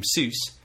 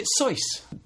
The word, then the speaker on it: Seuss: You're wrong as the deuce and you shouldn't rejoice. If you're calling him Seuss, he pronounces it Seuss.